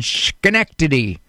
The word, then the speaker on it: Schenectady: They're serving Greater Albany and Schenectady. I pronounced that right.